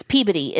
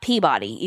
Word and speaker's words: Peabody: No, it's like, it's Peabody. It's not Peabody. Even though it's spelled Peabody, it's Peabody.